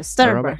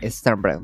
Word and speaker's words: Sternberg: No sé pronunciar bien eso. – Sternberg. Robert Sternberg.